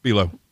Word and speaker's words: Bi-Lo: Come on, step right into B-Low. It's a fact. Our prices bring you in. Our people bring you back. B-Low. – Okay. Was that the one that was spelled B-I-L-O? – Yeah, B-Low.